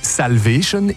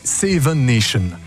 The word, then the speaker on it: Salvation: Et en anglais, Armée du Salut se dit Salvation Army, sauf que lorsqu'il était enfant, il était impossible pour lui de prononcer Salvation, il disait Seven Nation.